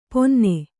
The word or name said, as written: ಪೊನ್ನೆ